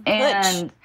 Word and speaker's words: putsch: What's a putsch? – How do you spell that? – It is P-U-T-C-H. – Yeah. – Yeah, I even looked up how to say it, and it is putsch.